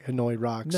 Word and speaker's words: Hanoi Rocks: Hanoi Rocks.